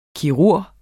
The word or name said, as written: kirurg